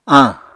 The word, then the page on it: añ: apatani